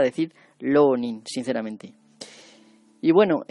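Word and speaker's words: rōnin: No se pronuncia así porque en japonés no se puede pronunciar ese sonido tan fuerte de nuestra R fuerte. Y se pronuncia siempre como la R suave, como la R suave o bien como L. Entonces sería Lonin, hay una O larga ahí, o bien Ronin.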